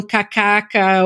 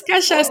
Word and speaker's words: cachaça: Eles falam, gente, eles falam cacaca ou cha-cha-cha. Eles falam tudo, menos cachaça.